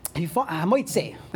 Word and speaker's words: Hamoitzi: Hamoitzi. That's the way to pronounce it.